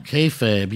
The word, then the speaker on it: kayfabe: Kayfabe, yeah.